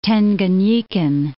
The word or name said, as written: Tanganyikan